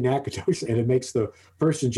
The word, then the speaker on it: Natchitoches: asking Google how to pronounce it, and they're coming back and fucking Google's pronouncing it Natchitoches.